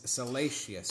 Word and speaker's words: Salacious: Corrected me, and it's Salacious. – Damn, this one. – That's just because I learned how to say it the wrong this way, one.